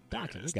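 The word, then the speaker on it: Doctson: Doxson. Got it. Like the dog. Like the little wiener dog.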